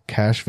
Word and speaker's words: Cache: Note, Cache Valley is pronounced Cache Valley, supposedly because a lot of mountain men left caches of their furs up there. – Oh, with a C-H-E. – Yeah. – I thought it was S-H.